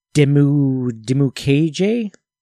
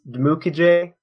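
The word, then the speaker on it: Dimukeje: Then we also have Victor Dimu KJ out of Duke. Does that sound right? – I think it's Mukije, but my pronunciation webpage doesn't have an entry for him, which is unfortunate.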